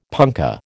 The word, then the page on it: punkah: American English